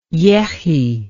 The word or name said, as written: yechy